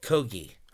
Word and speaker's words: Kogi: And so Roy Choi's blowing it up in California, doing the Kogi food trucks, or Kogi, how do you pronounce it? What do we know? – No idea. – Kogi. I'm going to go with the hard G.